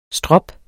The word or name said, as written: strop